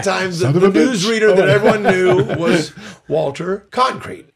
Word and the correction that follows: Cronkite: Walter Concrete. – Cronkite. Cronkite. I can't even say it right because I used to make fun of it. Cronkite. This is Walter Cronkite.